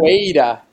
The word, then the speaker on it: capoeira: about capoeira. Am I saying that right? – The drink or the dance? – The dance, the martial art dance. Am I saying that? – Capoeira. – Capoeira. – That's it.